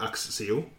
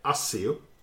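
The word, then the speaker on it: Accio: Det uttalas på tre sätt. – Mm-hmm. – Stephen Fry säger Axio. – Mm. – Jim Dale som läser upp de amerikanska böckerna. – Mm. – Han säger Axio.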